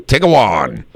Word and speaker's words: Tiguan: Tiguan.